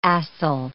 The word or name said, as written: acyl